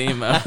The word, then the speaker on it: Imo: Yeah, it's called the Emo. But it's spelled IMO.